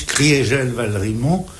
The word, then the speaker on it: Valrimont: On prononce Maurice Criégel Valrimont pour marquer que en 18, l'Alsace a cessé d'être germanisée.